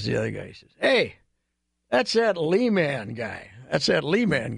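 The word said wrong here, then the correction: Lehman: And my wife, being the nosy person she is, said, it's pronounced Layman. She says to the guy, that's pronounced Layman.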